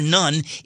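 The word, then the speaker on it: nun (ن): The Arabic letter pronounced nun stands for Nazarene, a derogatory reference to Christians in large swaths of the Middle East.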